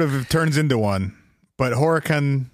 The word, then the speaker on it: Horicon: Horicon? Horicon? – Horicon? – H-O-R. – Yes. – I-C-O-N. How would you pronounce that? – Horicon.